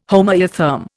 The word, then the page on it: homoeotherm: Indian